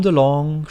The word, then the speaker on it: DeLonge: Mit Tom DeLonge, ich glaube, der heißt nicht so Tom wie diese Kaffeemaschine, DeLonge wird ausgesprochen.